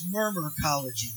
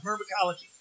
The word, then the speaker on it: Myrmecology: That's Murmurcology. There's two different things. This is Murmurcology. It's spelled M-Y-R-M-E-C-O-L-O-G-Y. Murmurcology.